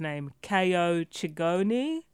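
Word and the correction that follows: Chingonyi: right? – Chiongi. – Chiongi, right, thank you.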